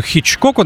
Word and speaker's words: Хичкоку: Caravan и во многом благодаря Дэвиду Хичкоку, наверное, все-таки так правильно ставить ударение в этой фамилии, э, Кэриван получили неограниченное время в студии, хотя это потом станет проблемой.